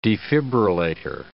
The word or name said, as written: defibrillator